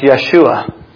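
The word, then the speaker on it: Yeshua (ישוע): In Hebrew, the name is pronounced Yeshua.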